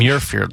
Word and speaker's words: Muirfield: Yeah, Muirfield Village. – Muirfield.